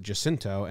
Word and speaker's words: Jacinto: because I would have done a soft J like Jacinto, or something like that. But then I listened to the dude who's on a show who's an actor who said it pronounced it Jacinto and San Jacinto. So it's a hard J, tricky, tried to throw me for a loop, but Jacinto Calvo is our baseball player of the day.